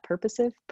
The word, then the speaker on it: purposive: Which they call anditive plus purposive. How would you say that? Purposive? Purposive. Yeah, purposive.